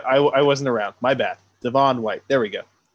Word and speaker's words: Devon White: Devon White. – Is it Devon White? – That's the way he pronounces it, yeah.